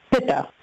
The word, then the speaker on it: Pitta: I think it's pronounced Pitta or Pitta? – Pitta, yes.